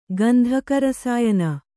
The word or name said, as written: ಗಂಧಕರಸಾಯನ